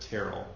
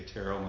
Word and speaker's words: Terrell: I will tell you, on Dancing with the Stars, they pronounced it Terrell because his dancing partner was Cheryl Burke, and they would say Terrell and Cheryl.